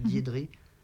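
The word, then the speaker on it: Giedrė: Oh, ouais, ça s'appelle G-I-E-D, elle s'écrit G-I-E-D-R-E, mais je crois que ça se prononce Diédré.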